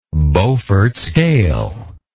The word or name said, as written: beaufort scale